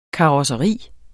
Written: karosseri

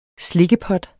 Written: slikkepot

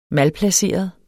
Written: malplaceret

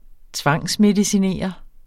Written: tvangsmedicinere

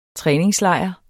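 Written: træningslejr

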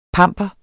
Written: PAMPERES

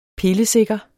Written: pillesikker

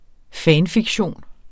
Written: fanfiktion

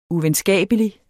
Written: uvenskabelig

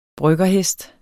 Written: bryggerhest

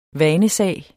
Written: vanesag

